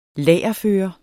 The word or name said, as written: lagerføre